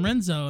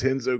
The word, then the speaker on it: Renzo: Or no, you pronounce it Enzo. – Oh, because of the Brazilian. – Because it's Brazilian. Yeah, all of them have R in front of their name. – Yeah, I've always called him Renzo, and I've heard other people say that too, but I didn't know that. – No, it's Henzo Gracie. It's Hoist Gracie. It's not Royce Gracie. So you know they all pronounce it with the H, or like it's kind of like an EH, like hello governor kind of shit, is from what I understand.